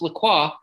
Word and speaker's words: LaCroix: By the way, and it is, I'm pretty sure, pronounced LaCroix, as someone named Jennifer LaCroix once told me. That would literally, her last name was LaCroix, spelled the same. And she was like, it's pronounced LaCroix, not LaCroix.